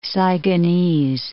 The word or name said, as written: Saigonese